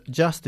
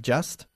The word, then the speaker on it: just: So the just is always going to go before the verb. Be careful with your pronunciation of just. Okay? We don't say just. It's just. – Just.